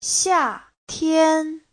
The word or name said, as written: xia4tian1